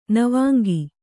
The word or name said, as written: ನವಾಂಗಿ